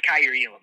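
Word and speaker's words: Kaiir Elam: It's Kyer Elam, like that's how you pronounce it. – Kyer Elam. – He's, yep.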